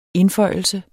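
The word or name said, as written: indføjelse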